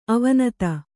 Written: ಅವಿನೀತ

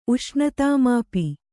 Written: ಉಷ್ಣತಾಮಾಪಿ

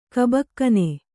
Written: ಕಬ್ಬಗಣ್

